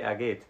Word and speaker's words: geht: You wouldn't pronounce the H so strongly in er geht. You can just say er geht.